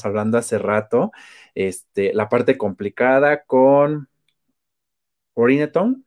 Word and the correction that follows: Urinetown: así se pronuncia. – Urinetown. – Urinetown, ok.